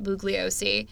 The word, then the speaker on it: Bugliosi: So for those of you who don't know, Helter Skelter was written by Vincent Bugliosi, and he was the did I pronounce his name wrong? – No.